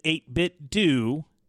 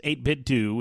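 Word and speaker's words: 8BitDo: is to pronounce it 8-bit Do, as in Scooby-Doo.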